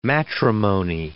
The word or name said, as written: matrimony